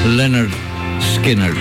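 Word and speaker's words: Lynyrd Skynyrd: Και ένα από τα πολύ αγαπημένα τους τραγούδια εδώ σε ηχογράφηση στον πρώτο τους δίσκο Pronounced Leonard Skinner.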